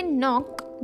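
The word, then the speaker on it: knock: यहाँ पे के साइलेंट होता है, तो आप लिखेंगे के न ओ नॉक.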